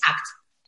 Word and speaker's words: ACT: Acceptance and Commitment Therapy es ACT se pronuncia.